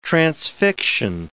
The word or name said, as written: transfixion